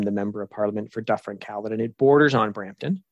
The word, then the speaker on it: Dufferin-Caledon: And you're currently the member of, I want to make sure I pronounce it properly, Dufferin-Caledon? – Yes, Dufferin-Caledon. – Dufferin-Caledon.